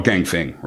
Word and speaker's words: Ganfeng: Oh, Ganfeng, right, okay, yep.